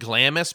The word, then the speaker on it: Glamis: It looks like Glamis, but they pronounce it Glamis.